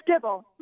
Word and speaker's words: Gibble: It's Gibble. – It's Gibble. – We are not friends. – I went with the soft G instead of the hard G, and I should have known better.